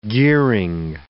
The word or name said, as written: gearing